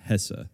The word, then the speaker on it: Hesse: I assume his name is pronounced Hesse. – Yeah, H-E-S-S-E. It seems like it.